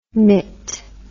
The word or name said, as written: mit